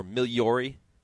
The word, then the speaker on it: Migliori: Miss Golden Circle, John Kimmel, Richard Migliori was aboard, or Migliori, as it is properly pronounced in Italian.